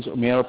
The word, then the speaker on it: Umair: My name is Umair Pasha. I'm going to spell that for you. It's U, yeah, U-M-A-I-R.